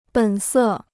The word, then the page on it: 本色: běn sè